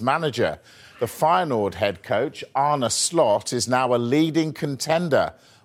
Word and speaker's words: Arne Slot: Arne Slot. – Det siste der for øvrig hvordan navnet hans uttales på nederlandsk, så la oss høre det en gang til. – Arne Slot.